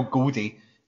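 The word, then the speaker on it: Gowdie: So I was looking into so basically this witch Isabel Gowdy came up because or sorry that's not even is it Goldie I think she she pronounces when I was chatting to her uh, uh Isabel Goldie uh,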